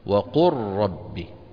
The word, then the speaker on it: وقل ربي: أيضا هذه الآية في أولها وقل ربي، إذا اجتمع لام ساكنة بعدها راء فإن العرب تدغم اللام في الراء ويصير النطق براء مشددة. وقل ربي، تمام؟